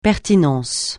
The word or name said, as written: pertinence